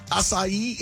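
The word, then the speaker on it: açaí: Acai. You said that right.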